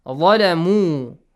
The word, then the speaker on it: ظَلَمُوا: ظَ heavy letter ظَلَمُوا an U sound, right? A long vowel sound قَوْلًا قَوْلًا.